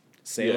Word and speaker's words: Selah: I think it's how do you say it, Salah Selah. Yeah, Selah. Selah.